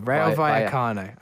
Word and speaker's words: Rayo Vallecano: A double L is pronounced as a Y. – Okay, Rayo Vallecano.